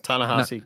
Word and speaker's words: Ta-Nehisi: Ta-Nehisi Coates.